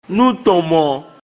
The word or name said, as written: Nútɔ̀nmɔ̆n